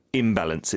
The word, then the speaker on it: imbalance: And yeah, just be careful with imbalance. It's IM, right? Not IN. – 맞아요. – 임발ance. – B 발음이다 보니까 앞에 반대말을 붙이는 접두사가 IM이죠. Imbalance. Okay? – One more time.